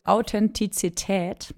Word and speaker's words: Authentizität: Ja, das Thema der heutigen Podcast-Folge ist Authentizität, mehr Sein als Schein. – Also erstmal Respekt, wie du dieses Wort ausgesprochen hast. Das ist nämlich nicht einfach.